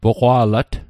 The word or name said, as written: poqwa'lat